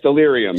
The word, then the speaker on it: Stellarium: But it's spelled like Stellarium. I know, it's very tough. I know. – Stellarium, because it says Steeler IUM. So Stellarium, which doesn't have to do with the Pittsburgh Steelers.